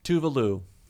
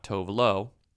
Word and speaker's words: Tove Lo: It's not news, to quote Tuvalu, that her name is Tuvalu and not Tovlo. We speak English in America, so when we see T-O-V-E-L-O, our impulse is to say Tovlo phonetically. But in Sweden, right. You say Tuvalu.